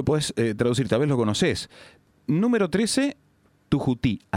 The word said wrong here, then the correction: Tuyutí: así se escribe. Discúlpame mi guaraní del interior. – 13 Tuyutí.